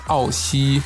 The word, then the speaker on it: Aoxi: Es wird geschrieben S-C-O-R. So findet ihr ihn auf Instagram. Wie ist die chinesische Aussprache? A-O-X-I geschrieben, glaube ich. – Genau, das ist ja S-C-O-R.A-O-X-I. Und das ist Aoxi.